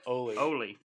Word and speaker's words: Ole: I said that wrong. – Ole. – Yeah. Ole Kirk. – Ole. Ole. Yeah, he's Danish. He's not Mexican.